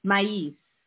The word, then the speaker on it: Sin Maíz: I can't speak Spanish, so I'm going to mingle this, but Sin Maíz, Sin Maíz, I think, something like this. – So it's S-I-N without, so Sin, S-I-N, Maíz is how it's pronounced.